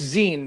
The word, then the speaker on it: zine: It's zine, man. Zine. As in magazine. – Zine, zine rhymes with green.